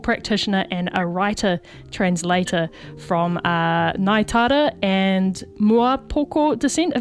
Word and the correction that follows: Muaupoko: Have I said that correctly, Sian? – Kia ora, Muaupoko. – Muaupoko, sorry about that.